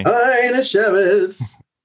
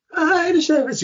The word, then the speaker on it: Hajnasiewicz: Hajnasiewicz. Hajnasiewicz. You got to sing it.